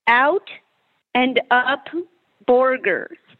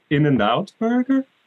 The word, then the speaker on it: In-N-Out Burger: Out and up burger. – That's how Germans do pronounce it. – That's a schnitzel. That's very great. Mm-hmm. It's In-N-Out Burger.